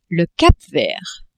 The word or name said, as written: Cap-Vert